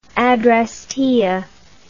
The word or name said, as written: adrastea